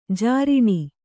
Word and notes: ಝರಣಿ: Kannada

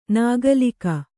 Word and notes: ನಗಲಿಕ: kannada

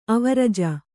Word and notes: ಆವರ್ಜಾ: āvarjā

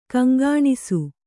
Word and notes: ಗುಂಗುಣಿಸು: kannada

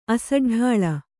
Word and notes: ಅಸಡ್ಡಾಳ: asaḍḍāḷa

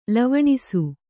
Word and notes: ಲವಣಿಸು: lavaṇisu

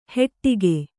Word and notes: ಹೆಟ್ಟಿಗೆ: heṭṭige

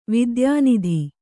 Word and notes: ವಾತಾಯನಿತ: vātayanita

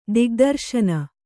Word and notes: ದಿಗ್ದರ್ಶನ: digdarśana

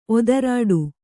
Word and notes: ಒದರಾಟ: odarāṭa

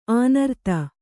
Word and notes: ಅನೂರಾಧ: Kannada

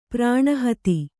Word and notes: ಪ್ರಣಿಹಿತ: praṇihita

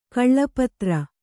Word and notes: ಖಲಪಾತ್ರ: Kannada

